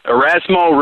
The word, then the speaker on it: Erasmo: I don't even know if I'm going to pronounce this correctly, but let's try with Erasmus Ramirez. – Erasmo Ramirez, the eraser. He pitched actually last year in the bigs with Seattle and did really well. A lot of our listeners will remember using Erasmo down the stretch last year.